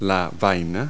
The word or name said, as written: husk